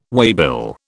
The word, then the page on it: waybill: British English